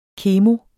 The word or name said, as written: kemo